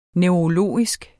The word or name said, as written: neurologisk